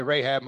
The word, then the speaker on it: Rahab: Likewise was not Rahab, however you want to pronounce it. I'll say Rahab. Most people say it that way.